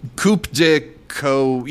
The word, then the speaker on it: coup de coeur: Then Mediterranean took home the screenplay award, and Rodeo took home the, what is it, the coup de coeur? Is that, am I pronouncing that correctly? I don't know French. – Is it coeur? – It's coup de coeur.